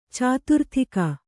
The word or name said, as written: ಚತುರ್ದಿಕ್ಕು